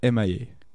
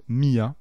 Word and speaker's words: M.I.A.: On va parler de M.I.A., qu'on connaît peut-être, qu'on prononce Mia, mais il faut dire M.I.A.